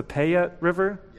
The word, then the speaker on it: Payette: I know, if memory serves me correctly, is it pronounced the Payette River? – Yes.